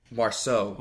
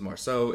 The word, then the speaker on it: Marceau: I think that's how you say it, because my experience in the French language, any word ending in the E A U, you pronounce as an O. – Yeah. – So I'm pronouncing it as Marceau.